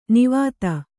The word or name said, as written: ನಿವಾತ